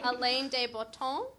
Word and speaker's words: Alain de Botton: Alain de Botton? – Yeah.